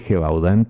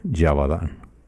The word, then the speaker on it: Gévaudan: Y se escribe Jebaudán, pero se escucha bien perro Yavadán.